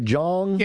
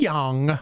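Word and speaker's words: Jeong: Is it pronounced Yong or Jong? – Yeah, it's Yong. – Yong. – Like comedian Ken Yong.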